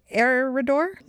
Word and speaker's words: Eriador: And interestingly, Lord of the Rings includes a country called Eriador. Is that pronounced correctly? – I believe so, yeah. – E-R-I-A-D-O-R.